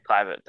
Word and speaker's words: Kluivert: Yeah, and I think there was a quote the other day from someone who was like, from Patrick Kluivert, I can't say his name. – Kluivert, thanks.